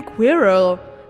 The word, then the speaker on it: Quirrell: Ich meine Quirrell. Du hast es falsch gesagt, es ist Quirrell.